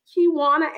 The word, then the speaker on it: Kiwana: Okay, so Joyce Kiwana Adams. Let me spell that middle name for you. K-I-W-A-N-A.